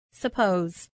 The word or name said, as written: suppose